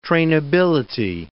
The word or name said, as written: trainability